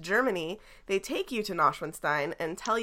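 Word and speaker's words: Neuschwanstein: How do you say it? – Nashwinstein.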